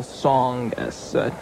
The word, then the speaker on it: Tsongas: Paul Tsongas. T-S-O-N-G-A-S. – Well, Mr. Tsongas. – The T is silent.